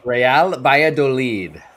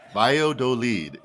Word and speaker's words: Valladolid: Valladolid. Say it one more time for me. – Real Valladolid. – Valladolid, exactly.